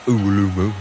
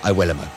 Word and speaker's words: Iwelumo: Commentary comes from Nigel Adderley and Chris Iwelumo. And yes, that is how you pronounce his name, not Uwelumo, as some people say, Iwelumo.